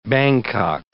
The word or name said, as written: Bangkok